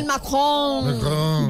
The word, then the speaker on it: Macron: Emmanuel Macron.